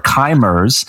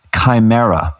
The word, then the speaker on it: chimera: She uses the word chimers, which, as it turns out, is a mispronunciation of the ancient mythic term chimera.